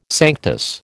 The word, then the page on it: sanctus: American English